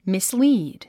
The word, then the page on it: mislead: mislíːd; ミスリード